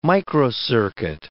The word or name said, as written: microcircuit